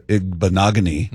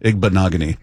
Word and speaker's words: Igbinoghene: And then also, if I'm Nehemiah Pritchett or Noah Igbinoghene. Mm-hmm. Is that how you say it? – Yep. – Igbinoghene.